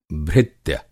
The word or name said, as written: भृत्य